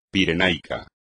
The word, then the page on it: pirenaico: espagnol